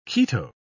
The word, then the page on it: keto: British English